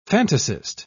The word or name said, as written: fantasist